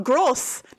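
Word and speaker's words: groß: Gross. That is how that would be pronounced. – Okay. – Yeah. If it's got that weird looking B, that's an S sound in German.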